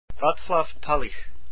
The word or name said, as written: Talich